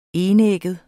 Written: enægget

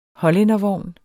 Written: hollændervogn

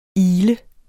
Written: ile

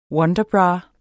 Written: wonderbra

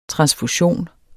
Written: transfusion

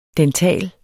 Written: dental

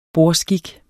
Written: bordskik

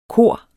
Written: kor

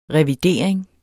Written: revidering

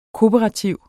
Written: kooperativ

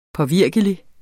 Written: påvirkelig

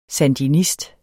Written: sandinist

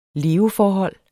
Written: leveforhold

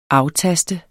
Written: aftaste

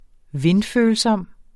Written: vindfølsom

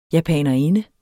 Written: japanerinde

